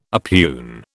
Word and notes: oppugn: British English

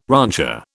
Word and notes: rancher: British English

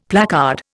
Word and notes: placard: Indian

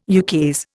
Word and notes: ukase: Indian

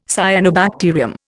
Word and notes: cyanobacterium: Indian

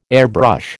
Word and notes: airbrush: American English

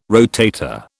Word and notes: rotator: British English

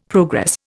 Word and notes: progress: Indian

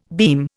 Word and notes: beam: Indian

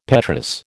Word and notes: petrous: American English

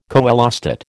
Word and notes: coelostat: American English

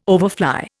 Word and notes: overfly: Indian

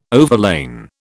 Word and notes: overlain: British English

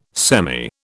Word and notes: semi: British English

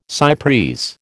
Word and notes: cy pres: American English

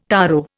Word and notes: taro: Indian

